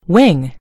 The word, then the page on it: wing: British English